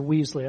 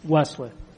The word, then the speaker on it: Wesley: The thing that comes up in my head is, John Wesley, or Weasley, I forget how you pronounce it, but he's one of, you know, I was reading this book, God's Generals, you know. – Wesley.